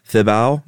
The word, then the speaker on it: Thibaw: On November 28th in 1885, this is when the British Imperial Army conquered Burma and said King, we're going to mispronounce some of these, Thibaw, maybe? – I think that's right.